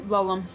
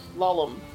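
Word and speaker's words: Lulham: How do you say that again? – Lollum. – Lollum, right, that English thing, Lollum, who was treated for thallium poisoning in 1952.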